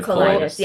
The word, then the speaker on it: colitis: And then your other large illness was colitis? Did I say that right? Ulcerative colitis.